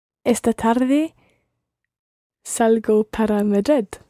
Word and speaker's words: salgo: It starts with salgo, salgo. – Salgo. – Yeah, it's one of those soft G sounds, so it's quite a salgo.